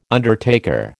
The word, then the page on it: undertaker: American English